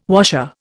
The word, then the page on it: washer: Indian